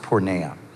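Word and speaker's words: porneia (πορνεία): So sexual immorality is the translation of a Greek word that is pronounced porneia.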